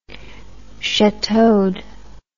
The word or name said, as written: chateaued